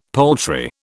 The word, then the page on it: poultry: British English